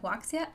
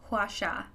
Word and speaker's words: Huaxia: Iron Widow is a mecha reimagining of the rise of Chinese Empress Wu Zetian, set in the nation of Huaxia. No, that's not how you say it. How do you say it? Huaxia.